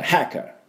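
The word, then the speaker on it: hacker: で、この歯のところにアクセントがありますので、あと絵の真ん中のおみえーとこの音ですね、でハッカーハッカーというふうに言わなきゃいけないし、なるべくこの音をちょっと入れて、寒い時の、手に息をかけるような音、これ H の音ですからね。だから、ハッカー。ハッカー。っていう風に言うと、綺麗な、ハッカーの発音にだいぶ違いますね。日本の音とはね。そこだけ要注意です。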